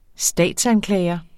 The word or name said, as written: statsanklager